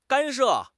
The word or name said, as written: 干涉